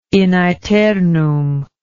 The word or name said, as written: in aeternum